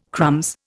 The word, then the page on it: crumbs: Indian